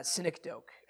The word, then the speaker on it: synecdoche: I think it's pronounced synecdoche. I think that it's like S-Y-N-E-C-D-O-C-H-E.